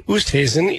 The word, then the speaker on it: Oosthuizen: Louis Oosthuizen is at 6-under.